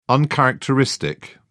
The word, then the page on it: uncharacteristic: British English